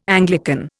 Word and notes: Anglican: Indian

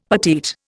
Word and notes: petite: Indian

